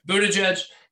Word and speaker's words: Buttigieg: Other participants include French President Emmanuel Macron, Transportation Secretary Pete Buttigieg, how the fuck do you say his name? – Buttigieg, Representative Dan Crenshaw, and they're like, apparently they have like a school, like a fucking pipeline for these guys, though.